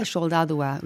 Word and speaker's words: Soldadua: Now, let's see if I'm going to pronounce this right. Neska Saldana. – Close. – Saldua. – It's Neska Soldadua, which is the girl soldier.